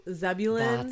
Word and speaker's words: Zebulun: Zebul- Zebulun.